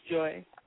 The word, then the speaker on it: Joi: Yes, it's Joi.